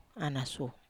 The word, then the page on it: ana su: Wamesa